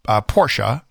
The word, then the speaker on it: Porsche: The correct way to say it is Porsche.